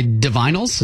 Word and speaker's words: Divinyls: I Touch Myself by Divinyls. Is that how you pronounce it? – Oh, yeah.